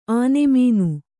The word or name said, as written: ಆನೆಮೀನು